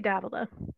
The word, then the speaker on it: Davila: How do you say your last name? Davila? – I say Davila.